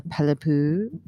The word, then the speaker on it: Palepu: I'm Hitha Pelipu.